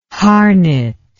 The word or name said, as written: Harnett